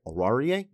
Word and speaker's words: Oruwariye: I love the matchups, by the way, between Antonio Brown and Chris Godwin going up against Amani Oruwariye. And I hope I said that right. Oruwariye? Yeah, that's it. Sure.